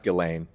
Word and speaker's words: Ghislaine: It's pronounced Ghislaine. – Ghislaine? – Yeah. – Sorry, I got Ghislaine's name wrong. Weird, that's who you defend. – Well, I didn't say I was defending her. I was just saying that's how you pronounce her name.